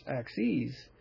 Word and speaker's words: axes: But in the case of axis with the is, the plural is axes also, which is pronounced axes. It's pronounced with a long e, so there's a difference there.